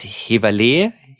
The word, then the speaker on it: Heverlee: Du als niederländisch sprechender Mensch kannst mir da vielleicht bei der Aussprache helfen. Ich glaube, es heißt Heverlee. Heverlee? – Ja, das passt ja schon.